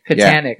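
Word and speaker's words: Kajganich: And then again, you know, I think is it David Kahanek? Is that how you pronounce his name? – Katanik.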